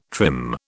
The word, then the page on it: trim: British English